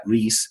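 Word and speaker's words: Ries: And so Positioning was by Jack Trouton and Al Rice, or Reese. I found out they pronounced that Reese.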